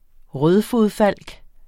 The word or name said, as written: rødfodfalk